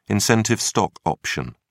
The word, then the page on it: incentive stock option: British English